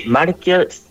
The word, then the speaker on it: Marquia: Sí, Marquia, que se escribe con Q. – Marquia. – IA.cl, sí. – Ah, punto CL, ya, sin una U entre medio, como diría el idioma español. Marquia, así nomás.